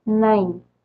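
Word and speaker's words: nein: ¿Sí, Acuérdense, para decir sí decimos ya y para decir no, nine.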